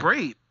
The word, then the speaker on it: Brate: Brandon Cooks, Cameron Brate. The tight end for the Bucks. I don't know how to say his last name. Brate? Brat? – Brate. It's B-R-A-T-E.